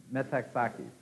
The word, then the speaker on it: Metaxakis: Meletius metoxicus. Is that right? Pronunciation? Metaxakis. Okay. Thank you.